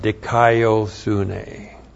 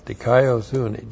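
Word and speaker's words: Dikaio sune (δικαιοσύνη): And then we have dikaio sune. And this is the theme of Romans is the righteousness of God. Dikaio sune. D-I-K-A-I-O-S-U-N long E.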